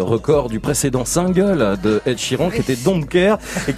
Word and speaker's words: Ed Sheeran: En tout cas, vous l'avez bien prononcé, Ed Sheeran, on dit bien Ed Sheeran.